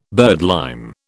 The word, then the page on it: birdlime: British English